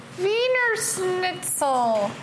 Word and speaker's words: Wiener Schnitzel: Wiener Schnitzel. Did I say it right? – Fuck yeah, nailed it.